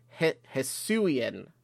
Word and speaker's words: Hisuian: So it's Hisuian. Did I say that right, Matt? Yeah. Okay, good. I got the thumbs up there.